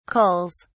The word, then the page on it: culls: British English